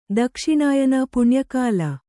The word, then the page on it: ದಕ್ಷಿಣಾಯನ ಪುಣ್ಯಕಾಲ: dakṣiṇāyana puṇya kāla